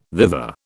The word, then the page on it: thither: British English